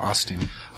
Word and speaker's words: Osteen: Osteen.